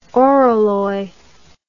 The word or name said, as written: oralloy